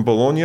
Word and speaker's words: Bologna: You're from Bologna, is that how you say it? – Yeah, this is where I grew up.